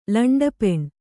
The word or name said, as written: ಲಂಡಪೆಣ್